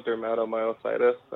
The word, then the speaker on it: dermatomyositis: dermatomyositis. Welcome. And first question, did I pronounce the diagnosis correctly, or can you correct me? – No, you got it good. It's juvenile dermatomyositis, and JDM might be a little bit easier.